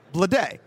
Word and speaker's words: Bleday: B-L-E-D-A-Y. Blade.